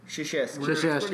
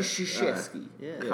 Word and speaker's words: Krzyzewski: Is he pronouncing that correctly? Is that how he say it? – Krzyzewski. – Krzyzewski. – Krzyzewski. – Pretty close. – We're gonna go with that. – Krzyzewski.